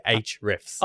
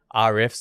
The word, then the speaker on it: Ahrefs: One thing I'll say, SEMrush does some things better than Ahrefs, and Ahrefs does some, and that's how you say it, by the way. That's how I've been told you're supposed to say Ahrefs. – Oh, is it? – Not Rfs.